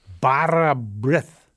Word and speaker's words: bara brith: I saw one of your recipes published about Welsh fruit bread, speckled bread, bara brith.